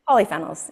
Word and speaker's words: polyphenols: Did I Yeah. – say that right? – Polyphenols. Yeah.